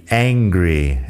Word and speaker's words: angry: La pronunciación ahí entre hungry y angry es importante diferenciarlo. Hungry tiene la H aspirada y una vocal más corta. Angry tiene una vocal algo más larga y bueno, sin la H, angry, de enfadado.